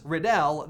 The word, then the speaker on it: Riddell: But the most notable has to be Tom Riddle's, which is slightly modified from Thomas Riddell. That's Thomas R-I-D-D-E-L-L. Pronounced Riddle, Riddell. So you can see the difference.